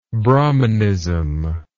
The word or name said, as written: brahmanist